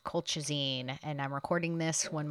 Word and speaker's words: colchicine: I noticed when I was editing this week's episode that I kept saying colchicine. I said it so many times, and I even knew how it's pronounced. It's pronounced colchicine.